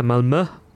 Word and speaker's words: Malmö: Our annual trip out to Malmö. – Yeah, Malmö, which I've learnt to pronounce just in time for our 10th visit out there.